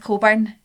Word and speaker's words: Cockburn: And her name was Mrs. Coburn, but I don't know if you guys know this, but Coburn is spelled Cockburn. – Mm-hmm. Cockburn, like a... – No, surely there's people called Cockburn as well. – No, it's always pronounced Coburn, right?